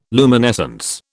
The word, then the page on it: luminescence: British English